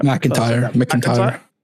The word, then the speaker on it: McIntyre: New Gears of War is getting announced in June, apparently, according to JD's voice actor, Liam McIngram. I said that right? – McIntyre. – How do I say that so wrong? McIntyre? Merck? Yeah, I look at Mick. – I think that's how it's pronounced, but I could McIntyre, be I think you're right.